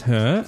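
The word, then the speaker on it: H.E.R.: Known professionally as Her, pronounced Her, an acronym for Having Everything Revealed.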